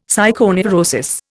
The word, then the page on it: psychoneurosis: Indian